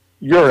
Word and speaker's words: Urus: No, Urus.